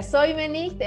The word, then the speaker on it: Seumenicht: bueno, estoy en, redes sociales con mi nombre, Brigitte Seumenicht. Es ah. complejo, se escribe con S, Seumenicht. Se pronuncia Soymenicht en,